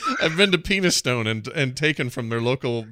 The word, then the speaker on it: Penistone: It's Penistone.